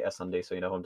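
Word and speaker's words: SSundee: Yeah, I'm pretty sure it's supposed to pronounce S-S-Undy. Because it's like a ship, like the SS, and then like undie, like underwear.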